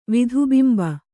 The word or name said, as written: ವಿಧುಬಿಂಬ